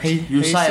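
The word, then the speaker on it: Hysaj: Hisaj siapa? Hisaj. – Husai bacanya.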